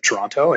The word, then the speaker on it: Toronto: So most people like here, or most people just say Toronto. Like the second T doesn't exist.